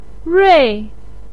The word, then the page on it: rui: 4